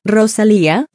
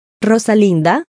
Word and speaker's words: Rosalía: Luego, a Fallon se le dio una lección sobre cómo rodar sus R para que pudiera pronunciar Rosalía como se pretendía. Ambos compartieron un apretón de manos para celebrar su habilidad para hacerlo en su primer intento. «Eso no está mal», dijo Rosalía antes de dar ejemplos de cómo la gente suele pronunciar mal su nombre. «He oído. Rosalinda, Rosalita, Rosalina».